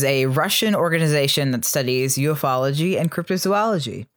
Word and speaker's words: ufology: It should be pronounced ufology, which is worse.